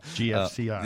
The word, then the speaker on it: GFCI: light switches or GCFI GF. Did I say that right? yeah. GFCI. Uh, there you go.